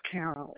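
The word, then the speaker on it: Carroll: Okay, so we have Ms. Carol, C-A-R-R-O-L, last name D-R-E-N-N-A-N. Not quite. The first name is odd. It's spelled just like a man's, Carol. It's C-A-R-R-O-L-L. – That's what I have. C-A-R-R-O-L-L. C-A-R-R-O-L-L.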